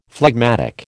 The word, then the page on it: phlegmatic: American English